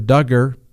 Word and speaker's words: Duggar: saying you get all this bad information that Mr. Duggar, rhymes with hugger, admitted to assaulting a 5-year-old, okay?